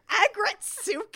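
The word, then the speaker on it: Aggretsuko: Agretsuko? – Agretsuko. – Agretsuko. Her name is Retsuko, and they put aggressive in Agretsuko.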